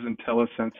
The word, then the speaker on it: IntelliSense: I hope I pronounced that right. IntelliSense technology. – You did indeed. It is IntelliSense, and I'd be glad to take that one.